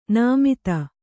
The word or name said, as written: ನೇಮಿತ